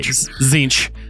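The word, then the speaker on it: Tzeentch: It begins with the T. T-Z-E. – It's just ignore the Z. Or ignore the T. – Okay. Zinch.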